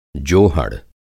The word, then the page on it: जोहड़: Hindi